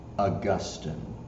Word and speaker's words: Augustine: Let me just stop and say right now, it's pronounced Augustine.